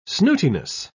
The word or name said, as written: snootiness